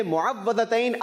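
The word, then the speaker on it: मुअव्वज़तैन: सही प्रोनाउंसिएशन है मुआवज़तैन, आपका जवाब दुरुस्त मान लेते हैं।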